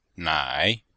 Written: nai3

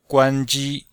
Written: guan1ji1

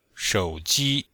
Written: shou3ji1

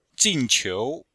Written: jin4qiu2

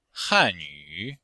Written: han4yu3

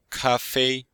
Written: ka1fei1

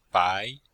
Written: bai2

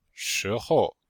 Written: shi2hou5

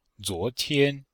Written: zuo2tian1

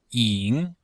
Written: ying3

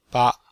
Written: ba5

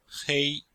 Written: hei1

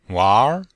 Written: wanr2

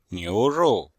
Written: niu2rou4

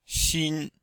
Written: xin1

